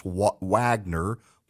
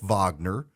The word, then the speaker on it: Wagner: It looks like it's Wagner, but it's pronounced Wagner, and they've gone to Syria.